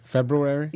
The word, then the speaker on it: February: I pronounce the R after the B. – February? – Yes.